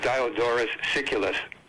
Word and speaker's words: Diodorus Siculus: And by the way, the name of that person I was trying to pronounce before I was having trouble is Diodorus Siculus.